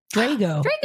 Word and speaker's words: Drago: And how would you pronounce this? D-R-A-G-O. – Drago. – Drago. I had Drago.